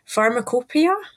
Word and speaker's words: Pharmacopoeia: So also in your book, you've got extracts of historical publications, including the Edinburgh, and I apologise if I say this wrong, Pharmacopoeia? – Oh, very well pronounced. Absolutely perfect.